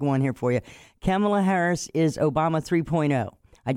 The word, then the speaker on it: Kamala: It is Kamala.